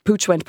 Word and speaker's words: Puig: Yes, PUIG, Pooch. No one knows how to say it or spell it. It's a Catalan, so they're a family from Barcelona, an incredibly entrepreneurial family, over 100 years in business.